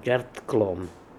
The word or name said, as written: Djartklom